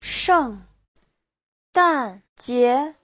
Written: sheng4dan4jie2

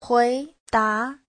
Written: hui2da2

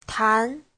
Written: tan2

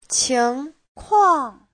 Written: qing2kuang4

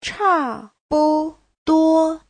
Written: cha4bu5duo1

